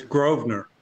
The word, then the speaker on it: Grosvenor: It's Grosvenor. The S is silent. – Grosvenor. This is why I didn't say it. I would have got it wrong. – Grosvenor.